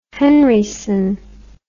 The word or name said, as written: henryson